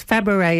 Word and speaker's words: Fabares: Fabares is the correct pronunciation, is it? – Fabares, yes. Yes, it is.